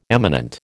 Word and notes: eminent: American English

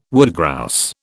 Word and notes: woodgrouse: British English

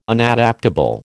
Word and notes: Unadaptable: American English

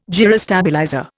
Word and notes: gyrostabilizer: Indian

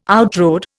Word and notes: outrode: Indian

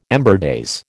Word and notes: ember days: American English

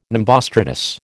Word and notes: nimbostratus: American English

